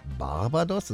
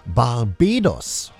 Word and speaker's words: Barbados: Barbados ist richtig, aber in Englisch nicht. Falsch ausgesprochen, ne? Barbados heißt diese Inselformation.